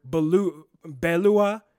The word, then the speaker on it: Beloit: And actually, if you want to know the truth, we don't really pronounce Beloit correctly. It's actually a French word, Beloit, and it's similarly like Detroit, which is actually Détroit. And a lot of people don't know this. Obviously, we butcher it because we do the English pronunciation right.